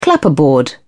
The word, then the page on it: clapperboard: British English